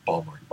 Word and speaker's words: Baltimore: See, Baltimore is spelled like Baltimore. That's how it should be pronounced. How do we say it? – Balmer. – Balmer.